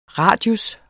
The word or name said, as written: radius